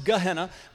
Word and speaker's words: Gehenna: The next one is Gehenna, that's often pronounced Gehenna.